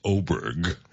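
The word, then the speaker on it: Åberg: And it's funny because the name is spelled A-B-E-R-G, but it's Oberg is how it's pronounced.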